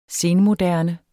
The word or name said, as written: senmoderne